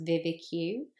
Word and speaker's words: VVIQ: The one I used is, it's pronounced VIVIQ, but it's basically the letters V-V-I-Q.